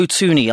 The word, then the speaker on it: Thuney: Joe Thuney. I've pronounced that correctly, haven't I, Joe? – Yes, the H is silent. Most people mess that up, so I'm very impressed.